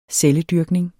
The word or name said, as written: CELLER